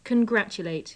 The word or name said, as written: congratulate